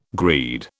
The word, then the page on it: greed: British English